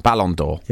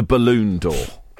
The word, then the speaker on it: Ballon d'Or: Yeah, for instance, the Ballon d'Or is a well-regarded footballing prize for the greatest footballer in the world, and it's spelled B-A-L-O-N-D-O-R, Ballon d'Or. – Yeah. – And I had to field the tweets from listeners yesterday complaining that she'd called it the balloon door.